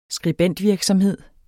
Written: skribentvirksomhed

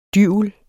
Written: DYVELEN

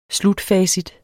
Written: slutfacit

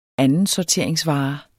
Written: andensorteringsvare

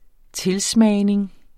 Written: tilsmagning

